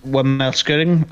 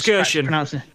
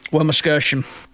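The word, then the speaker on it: Wermelskirchen: He's born in Wormelskirchen. I butchered that completely. Let's try that again. I tried to pronounce Wormelskirchen. – Thank you. Wormelskirchen.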